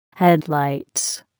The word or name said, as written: headlights